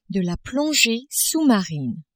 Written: plongee sous-marine

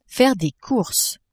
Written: faire des courses

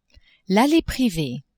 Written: allee privee

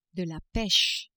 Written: peche

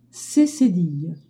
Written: c accent cedille

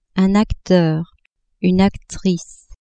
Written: acteur, actrice

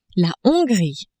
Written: Hongrie

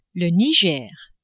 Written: Niger

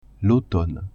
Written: automne

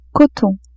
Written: coton

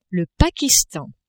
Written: Pakistan

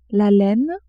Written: laine

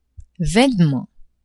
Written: vainement

